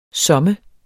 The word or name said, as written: somme